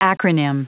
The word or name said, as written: acronym